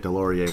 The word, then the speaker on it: Delorier: Who could forget Delorier when it rhymes with DeLorean?